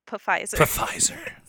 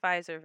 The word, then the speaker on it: Pfizer: Or the Pfizer. In Germany, they pronounce the P's.